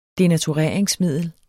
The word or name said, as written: devovere